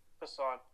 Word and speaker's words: Passan: Passan.